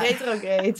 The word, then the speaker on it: retrograde: Ehm, ik zie wel, wel eens voorbij komen allerlei posts en dingetjes over retrogates. Grades. Retro, hoe spreek je het uit? – Ja. Retrograde. Ja.